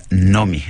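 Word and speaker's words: Noomi: En sueco se pronuncia Nomi, Nomi Rapaz.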